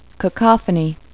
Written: cacophony

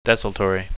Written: desultory

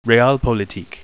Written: realpolitik

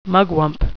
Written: mugwump

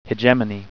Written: hegemony

